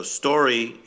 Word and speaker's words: Storey: So story, in my case, is spelled Storey.